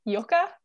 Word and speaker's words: yucca: It's spelled Y-U-C-C-A. So yucca? – Yeah, yucca.